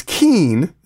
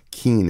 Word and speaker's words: Ceann: It's Keen is the name of the band. It's spelled C-E-A-N-N. Keen is how it's pronounced.